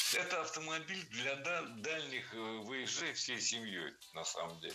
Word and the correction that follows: вояжей: Выезжей – неверно. Ударение на окончание переносить не следовало. Правильный вариант – вояжей. Ударение всегда сохраняем на «я» во втором слоге. Вояжи, вояжей, вояжами.